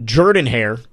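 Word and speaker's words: Jordan-Hare: This week you get Auburn in, we've figured out Jordan Hare, apparently is how it's supposed to be pronounced.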